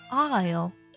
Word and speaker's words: aisle: 那类似的单词呢还有比如说过道，这个单词拼作 aisle，这个单词当中的同样字母 s 是不发音的，读作 isle。